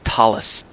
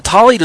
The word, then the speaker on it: tallit: And just say it like the two words, tall as opposed to short, and eat as opposed to drink. So tall, eat. Well, a tallit is also known as a prayer shawl, and it is a four-cornered, rectangular-shaped garment.